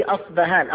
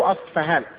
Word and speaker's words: أصبهان: أصبهان أو أصفهان تنطق بالبي اللي أشبه بالبي الإنجليزية أو بالفاء